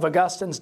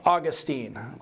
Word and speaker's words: Augustine: and he always pronounced it St. Augustine, like the city in Florida. I like the young Augustine, kind of a jerk. Sense that you were supposed to say Augustine, not Augustine, and I always did that just to annoy him.